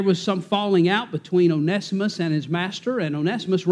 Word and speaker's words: Onesimus: Now, Brother Mike, I pronounce it Onesimus,